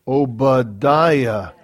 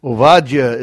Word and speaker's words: Ovadia: Oh, it's, we say it starts with an O. Obadiah. Ovadia is how it's pronounced in Hebrew.